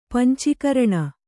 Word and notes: ಪಂಚಕರ್ಣ: panca karṇa

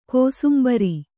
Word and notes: ಕೋಸುಂಬರಿ: kōsumbari

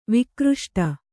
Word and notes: ವಿಕ್ರುಷ್ಟ: vikruṣṭa

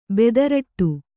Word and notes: ಬೆದರಟ್ಟು: bedaraṭṭu

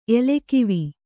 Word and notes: ಎಲೆಕಾವು: elekāvu

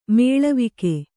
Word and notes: ಮಲವೇಗ: kannada

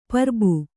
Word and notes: ಪುರ್ಬು: purbu

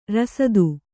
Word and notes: ರಾಸ್ತ: rāsta

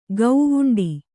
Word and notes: ಗೌವುಂಡಿ: gauvuṇḍi

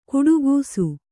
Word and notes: ಘಟಾಕಾಶ: ghaṭākāśa

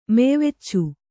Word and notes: ಮೆಯ್ವೆಚ್ಚು: meyveccu

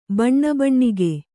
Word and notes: ಬಣ್ಣಾಬಣ್ಣಿಕೆ: baṇṇābaṇṇike

